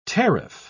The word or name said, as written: tariff